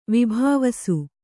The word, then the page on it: ವಿಭಾವಿಸು: vibhāvisu